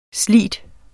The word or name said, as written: slig